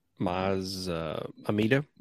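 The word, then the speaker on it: Maz Amedda: How do you say his name? – Maz Amida, right? – Yeah, there you go. I always heard Maz Amida.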